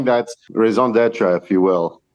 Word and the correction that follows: raison d'être: If I actually pronounce that correctly. – Raison d'être. – Oh, I'm sorry. You're closer.